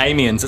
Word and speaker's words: Amiens: And so, yes, you play the role of Amiens. Correct me if I'm wrong. Is that how you pronounce it? – Yeah, Amiens. It's kind of like my name with the last letters missing.